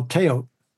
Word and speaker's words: teotl: Well, it begins with this notion that the world is basically consists of power, force, energy, and that what they call teotl, T-E-O-T-L, the L is silent.